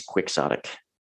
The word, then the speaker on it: quixotic: I think it is quixotic, because it's not a Spanish word. It's not Quixotic. That's not a word in Spain. That's not a word in Spanish.